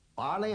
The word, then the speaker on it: barley: Remember to pronounce the EY in barley with the sound of the EY in key. – We grow grain crops in this part of the United States. Wheat, corn, barley and rye.